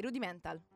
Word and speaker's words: Rudimental: Mill dei Rudimental. L'ho detto giusto? – Sì, sì, perfetto.